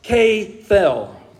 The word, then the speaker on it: kepel: The Hebrew term that here in the English is, is written out K E P E L. It's pronounced K fell.